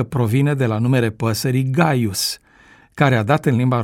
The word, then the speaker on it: Caius: În ceea ce privește numele Caius, acesta se pronunța Gaius, dar se prescurta cu litera C, pentru că așa cerea vechea regulă a prescurtării. De aceea s-a ajuns mai târziu la pronunția Caius.